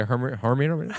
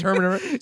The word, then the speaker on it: Hermione: Yeah, I was like, if you asked me to say what her name was, I'd be Hermione. It's Hermione.